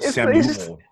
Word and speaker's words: Samuel: um i believe this name this gentleman is say muel uh l jackson uh, Jake's pronounced Samuel. Samuel. – Samuel. – Okay. Samuel Jackson.